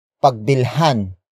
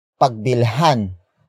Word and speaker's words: pagbilhan: Another example, pagbilhan ng tindahan. Pagbilhan ng tindahan. Pagbilhan ng tindahan. It means buy at the store. The focus is on the store. Word for word it's pagbilhan. Ang tindahan. Pagbilhan.